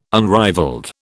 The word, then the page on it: unrivalled: British English